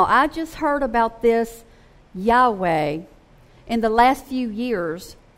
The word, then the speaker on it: Yahweh: I usually pronounce it Yahweh.